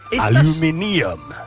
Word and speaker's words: aluminium: Quite literally, aluminium is actually pronounced how it's spelt. Aluminium.